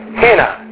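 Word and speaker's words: Hina (ἵνα): And the next one, now, do you notice the breathing mark on the next one? So, yeah. So how would you say that? It's got that H sound. Hina.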